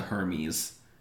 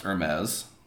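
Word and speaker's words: Hermès: Tony sheepishly saying it's the Hermes, in like he's a Muppet or something. – Yeah, it's supposed to be the best. – First of all, it's pronounced Hermes.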